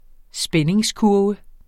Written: spændingskurve

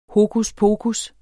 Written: hokuspokus

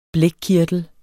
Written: blækkirtel

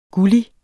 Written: gullig

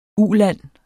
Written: uland

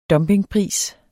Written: dumpingpris

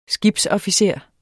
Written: skibsofficer

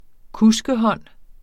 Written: kuskehånd

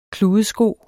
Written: kludesko

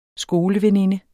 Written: skoleveninde

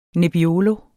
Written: nebbiolo

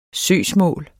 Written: søgsmål

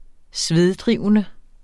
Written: sveddrivende

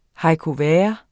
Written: haricots verts

